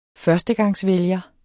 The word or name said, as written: førstegangsvælger